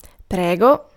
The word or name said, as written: prayer